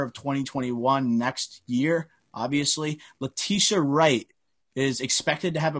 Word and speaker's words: Letitia: Letitia.